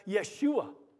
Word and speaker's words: Yeshua: You would pronounce both names in Hebrew as Yeshua, which means Jehovah or Jehovah saves.